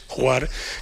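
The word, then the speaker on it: Hvar: No sé bien cómo se pronuncia, Juar, ¿no? Es... – una isla que se llama, se escribe H-V-A-R, y yo creo que se pronuncia como Juar o algo así, pero claro, este sonido de cigarras me lleva a eso, al sí.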